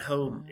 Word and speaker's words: Holm: And Home is spelled H-O-L-M, and it means it's an old Norse word for like island in an estuary.